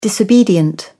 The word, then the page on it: disobedient: British English